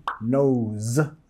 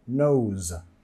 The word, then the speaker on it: nose: De la pronunciación nose. Z. Nota el sonido Z al final. Nose.